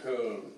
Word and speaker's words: Köln: I was in a German nightclub in Cologne, or as they say in Germany, Köln, it's pronounced. K-O-L-N. And on top of the O, my friends, two little salamander eyes.